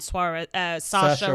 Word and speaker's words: Saoirse: Margot Robbie and Saoirse Ronan. Yeah. I always want to say it Suarez. – Ronan. – No, it's spelt Suarez, but it's Saoirse Ronan.